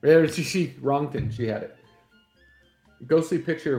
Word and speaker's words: Röntgen: Oh, it's pronounced Röntgen.